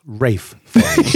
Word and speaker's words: Ralph Fiennes: If you say Ralph Fiennes, you're wrong. His name is Welsh. It's pronounced Rafe Fiennes.